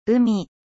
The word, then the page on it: mer: French